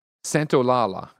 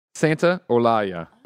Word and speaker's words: Santolalla: On, I did a check. Santolalla. – Santolalla. – Here, spelled phonetically. Santolalla. – Oh, Santolalla. Okay, cool.